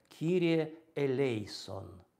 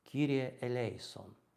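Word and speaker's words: Кирие элейсон (Κύριε ἐλέησον): И поэтому вот по-гречески «Господи помилуй» будет звучать так «Кирие элейсон». «Кирие элейсон».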